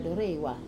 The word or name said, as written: dorewa